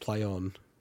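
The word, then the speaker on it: Plaion: on, I guess, but then it's ambiguous because is it P-L-A-Y? Yeah. – No, it's play on. – I know, it's P-L-A-I-O-N. – No, but they want it to be pronounced play on.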